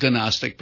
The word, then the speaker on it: Gnostic: Gnostic gospel, spelled Gnostic, but don't pronounce it that way.